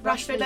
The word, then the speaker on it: Rashford: Rashford, is it Rashford? – Yeah, Rashford.